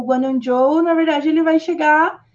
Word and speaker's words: Zhou: Mas eu acho que é Zhou. fala tipo como se fosse um D, um DJ.